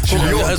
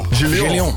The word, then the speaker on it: Julion: Ja, ik moet het dus op het Frans uitspreken. Ik zag het natuurlijk alleen maar op TikTok, hè. Dus ik gokte een beetje... – Julio, toch? – Julion. – Julion. – Ja. Mensen spreken het wel eens verkeerd uit, maar het is gewoon Julion eigenlijk.